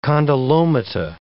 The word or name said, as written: condylomata